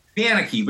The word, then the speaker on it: Manecke: Panicky, but you're close enough.